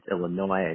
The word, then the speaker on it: Illinois: So for our listeners who may not be as familiar with your community, and I'm so impressed with myself that when I pronounced Illinois, I didn't pronounce the S, but could you please share with us a brief overview of the shul and the community in general? – Sure.